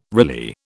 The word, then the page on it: Riley: British English